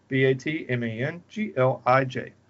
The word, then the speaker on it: Batmanglij: So, I'm going to butcher the director's name. So here we go. Zal Batmanglish. Batmanglish. I think I got it. Batmanglij. – Sounds good to me.